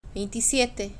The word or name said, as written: veintisiepte